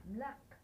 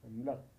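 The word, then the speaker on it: Mlaq (ملق): Mlaq. – The How do you spell that? – Mim lam qaf. – Mlaq. – Mlaq.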